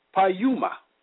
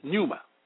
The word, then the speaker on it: pneuma: The Greek word for spirit is pronounced pneuma, which is pneuma, meaning a current of air, breath or breathe, an angel, demon, or divine God, Christ Spirit, Holy Spirit, ghost, life, spirit, mind.